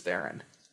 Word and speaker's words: Theron: But people would try to pronounce it with this like extravagant way, and then it turns out it was just Theron.